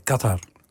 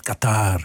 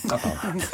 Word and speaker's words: Qatar: Het is Qatar. – Qatar. – En niet Qatar. Of, Qatar. – Klemtoon op de eerste A.